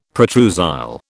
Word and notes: protrusile: British English

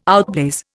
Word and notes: outblaze: Indian

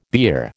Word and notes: beer: American English

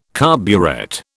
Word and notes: carburet: British English

